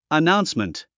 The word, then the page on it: announcement: ənáunsmənt; アナウンスメント